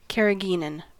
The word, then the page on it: carrageenan: American English